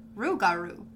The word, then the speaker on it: rougarou: Pronounced Lugaru and Rugaru, respectively. These words rhyme with each other because Rugaru is the variant of French Lugaru.